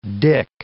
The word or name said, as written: dick